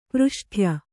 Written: ಪೃಷ್ಠ್ಯ

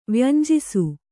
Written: ವ್ಯಂಜಿಸು

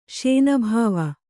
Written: ಶಾನಬಾವ

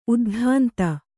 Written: ಉದ್ಧಾಂತ

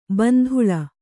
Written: ಬಾಂದಳೆ

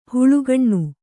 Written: ಹಾಲುಗಿಣ್ಣು

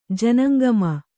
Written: ಜನಂಗಮ

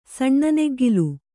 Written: ಸಣ್ಣನೆಗ್ಗಿಲು

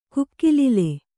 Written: ಕುಕ್ಕಿಲಿಲೆ

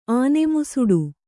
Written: ಆನೆಮುಸುಡು

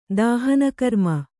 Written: ದಾಹನಕರ್ಮ

